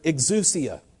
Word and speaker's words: exousia: The word authority there is the Greek word exousia. Exousia, it's spelled E-X-O-U-S-I-A, for those of you who like to write stuff like that down.